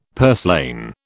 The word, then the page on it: purslane: British English